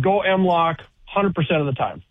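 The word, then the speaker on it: M-LOK: Go M-Lock. It rhymes with Glock.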